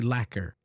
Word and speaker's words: lacquer: For example, it's, by the way, the spelling is L-A-C-Q-U-E-R. It has a lot of word. Actually, the U is silent. We say lacquer.